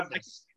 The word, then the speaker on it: Abmas: I hope I pronounced that right. Mac, Ab, Max Abmas. Mac, Ab, yeah, Abmas. – Yes, thank you.